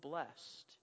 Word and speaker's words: blessed: 9 times, blessed, one word, one syllable, blessed.